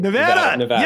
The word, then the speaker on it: Nevada: and Nevada. I said it correctly. – Nevada.